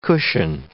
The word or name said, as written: cushion